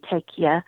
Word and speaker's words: Takeya: Is that how you say it? Yes, T-A-K-E-Y-A, tequila.